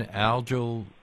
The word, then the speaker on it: algal: So there was an increase in algal, and is it algal or algal? – Algal is probably the more common pronunciation.